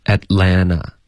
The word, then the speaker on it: Atlanta: 那你就说 Atlanta 就不读 Atlanta 了，那两个 T 都会省略。在美式的发音中两个 T 都肯定省略。 – 那会读成什么呢？ – Atlanta。